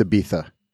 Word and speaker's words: Ibiza: It's Ibiza, Joe. Say it right. Ibiza.